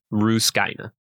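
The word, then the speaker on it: Rusukaina: Luffy is now going to be doing his training arc with Rayleigh on an island called Ruskaina, by the way, in case you're wondering how to pronounce it. Ruskaina.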